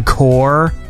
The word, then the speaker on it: Corps: Oh, you don't pronounce the P when it's with the S, right? Like the Marine Corps, even though it's spelled corpse.